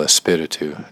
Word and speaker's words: Espíritu: Emphasis on the I. El Espíritu de Jesucristo.